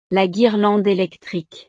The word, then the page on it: la guirlande electrique: French